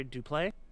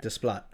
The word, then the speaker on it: Desplat: So we have Dunkirk, who is done by Hans Zimmer, Phantom Thread, Jonny Greenwood, Shape of Water, Alexandre Duplay. Is that how you say it? Duplay? – Probably. – All right. Desplat.